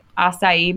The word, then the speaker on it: acai: They have lemon lime and acai berry. Is that how you say that? – Yes, you nailed it.